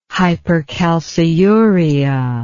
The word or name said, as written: hypercalciuria